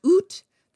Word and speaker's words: ut: The ut was originally an adverb pronounced oot that meant out.